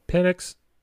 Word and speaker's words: Penix: Penix.